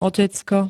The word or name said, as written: otecko